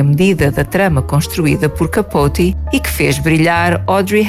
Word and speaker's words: Capote: Trata-se de uma novela do escritor Truman Capote, ou Capote, como se pronuncia no seu país natal, os Estados Unidos.